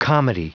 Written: comity

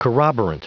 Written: corroborant

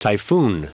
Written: typhoon

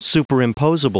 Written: superimposable

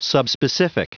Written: subspecific

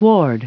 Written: ward

